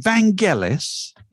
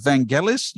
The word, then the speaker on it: Vangelis: Were you aware of Vangelis? You know much about Vangelis? You ever have a Vangelis record, for instance? – I've noted the name. For sure. I always thought it had a soft G, which shows how much I know.